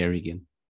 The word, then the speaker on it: Eregion: Yeah, he was a smith of Eregion, or Aragon, or I'm not sure how you pronounce it. I say Eregion.